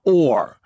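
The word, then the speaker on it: Oher: And his name is O'Hare. How you pronounce his last name? – I think it's just Michael Orr. – Just it's pronounced Orr.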